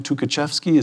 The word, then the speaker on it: Tukhachevsky: Tukhachevsky, is that how it's pronounced? – Yes, that's right.